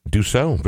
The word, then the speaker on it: Dussault: Is it Dussault? – Dussault. – Oh, the proper French pronunciation.